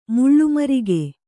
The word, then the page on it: ಮುಳ್ಳುಮರಿಗೆ: kannada